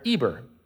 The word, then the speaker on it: Eber: Eber is also sometimes written with an H, like a silent H, like we say the word herbs, but we just say herbs, even though there's an H in it.